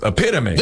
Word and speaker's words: epitome: Apparently, several years ago, when Alex Trebek was hosting, when we were still living, the answer to one of their clues was epitome. – This word for a perfect example doesn't rhyme with home.